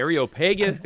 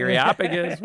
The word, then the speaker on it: Areopagus: And they took him and brought him to the Areopagus, saying, by the way, thank you for pronouncing that for me. – Yeah, I know. – How do you say that? Okay. – The Areopagus, the Areopagus.